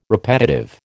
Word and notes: repetitive: American English

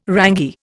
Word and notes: rangy: Indian

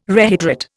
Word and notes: rehydrate: Indian

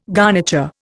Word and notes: garniture: Indian

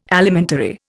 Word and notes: Alimentary: Indian

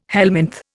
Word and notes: helminth: Indian

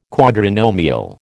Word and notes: quadrinomial: American English

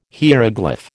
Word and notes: hieroglyph: American English

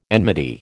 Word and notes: enmity: American English

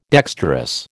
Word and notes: dexterous: American English

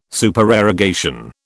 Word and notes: Supererogation: British English

